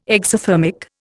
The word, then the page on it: exothermic: Indian